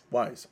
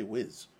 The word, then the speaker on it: WYZ: How do you pronounce this? – Wise. – I keep wanting to say Wiz. – It's W-Y-Z. – I know, but Wiz. Like, I just look at it, I was like, Wiz. – I know, but you're going to have people Google searching Wiz. It's Wise. W-Y-Z.